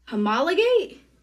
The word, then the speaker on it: homologate: Okay, and I'm totally going to butcher this word. Homologate? – Oh, you nailed it.